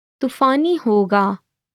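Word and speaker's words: tufani hoga (तूफ़ानी होगा): It will be stormy is tufani hoga. Tufani hoga. Let's break it down by a syllable. Tu-fa-ni hoga.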